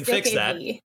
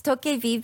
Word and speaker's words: DokeV: I think it's Dokev. – What is it? – I think it is Dokev. – Yeah, okay. It's Dokev because it's a playoff of Pokevi, which means goblin in Korea.